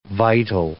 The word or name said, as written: vital